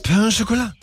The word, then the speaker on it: pain au chocolat: Das ist das Pain au chocolat. – Das Pain au chocolat? – Ja. – Ich bin gar nicht so doof. – Nein, du hast alles richtig gesagt.